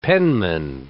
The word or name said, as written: penman